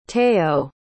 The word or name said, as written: teo